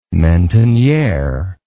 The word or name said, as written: mentonniere